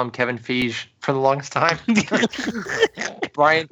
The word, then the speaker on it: Feige: And then I would say Feige.